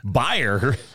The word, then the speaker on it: Bayer: Our presenting sponsor, Bayer and AARP. – Bayer? – It's Bayer. Bayer. Bayer. Bayer. Bayer's the microphone that's spelled the exact same way.